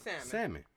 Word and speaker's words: salmon: I gotta teach my kids salmon. – Phonetically, the L is not supposed to be there. – Yeah. – Yeah, it's salmon. You're not saying the L.